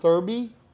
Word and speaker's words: Thurby: Thurby? – Like Thursday Derby. Thurby. Right?